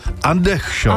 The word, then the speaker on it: Andersson: Písmena R a S v tomto případě splývají dohromady, přičemž ve výslovnosti R být úplně nemá, takže tam se R nevyslovuje, takže Larsson by měl znít jako Lašon. – Lašon, ještě nevíc. Nebo Andersson by měl znít jako Andechson.